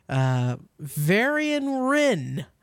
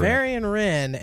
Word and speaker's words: Varian Wrynn: So Heroes of the Storm, if you're a Heroes of the Storm player, Varian Wrynn. Is that how you say it? – It is. – Yep. – You are correct. – Varian Wrynn and Ragnaros is going to be added to the lineup.